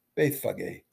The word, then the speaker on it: Bethphage: Jesus sent two of his disciples to run out ahead to the tiny village of Bethphage, or as they would have pronounced it, Bethphage.